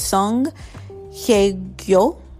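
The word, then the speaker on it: Song Hye-kyo: All right, next they have So Hye. I always mispronounce her name, but it's Song Hye Gyo.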